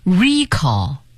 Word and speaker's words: recall: 我们再来看看它的名词形式，读成 recall。